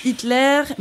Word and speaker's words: Hitler: ça se prononce Hitler, mais il n'y a pas d'accent sur le e.